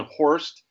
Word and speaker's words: Horst: It's the O. I think you said Hurst. It's a stump in Horst, and kids call me a horse.